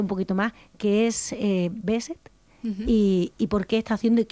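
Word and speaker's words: Behçet: Os voy a presentar a Nuria Zurdo, que, bueno, es enferma de Besset. No sé si lo he dicho bien, Nuria. – Sí, sí.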